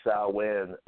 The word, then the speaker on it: Samhain: Samhain is also known as Samhain. It's pronounced in Gaelic.